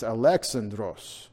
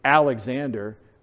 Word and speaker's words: Alexandros: It's Alexandros. Alexandros, like in Russian, Alexei. They put the emphasis on the second syllable. We say Alexander. They say Alexandros.